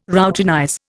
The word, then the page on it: routinize: Indian